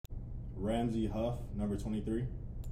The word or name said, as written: Ramsey Huff